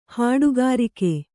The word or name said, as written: ಹಡುಗಾರುಕ್ಕೆ